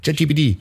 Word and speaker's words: ChatGPT: Das ist die mitunter schwierige Aussprache vom KI-Chatbot ChatGTP. – Ja. – ChatGPT.